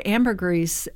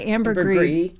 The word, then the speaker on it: ambergris: It's funny, you said ambergris, and I say ambergris, and it can be pronounced both ways.